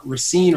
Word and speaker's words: Racine: My stepdaughter, she's in Racine right now. Is that how you say it? – Yeah.